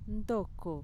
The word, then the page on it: ntoko: Mixtec